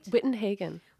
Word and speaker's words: Wittenhagen: Did I say that right? – Wittenhagen.